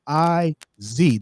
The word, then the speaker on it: iazY: But this lady right now, iazY. Did I say that right? Did I introduce you right? – Yes, you did.